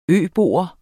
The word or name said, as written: øbo